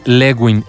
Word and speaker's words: Lewin: El autor que te podemos referir en este liderazgo es Kurt Lewin, así como se escribe con K-K-U-R-T, Kurt, ¿no? Pero se dice Kurt y Lewin, L-E-W-I-N.